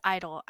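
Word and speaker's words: (G)I-DLE: Also, it's Idol. I keep saying the name wrong because of the G. – It's just Idol.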